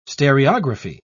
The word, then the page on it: stereography: British English